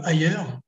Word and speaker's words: Haier: Bien, il y a un exemple, c'est l'entreprise Ayer donc ailleurs, ça s'écrit H-A-I-E-R, hein, pas, pas, comme ailleurs.